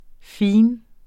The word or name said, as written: fin